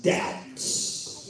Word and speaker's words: doubt: Webster defines weather as a conjunction that reports conditions and expresses, this is the part got me, expresses doubts. – Yeah. – What is doubts? In fact, doubt is the only word that I know of in the English language that's got a silent B.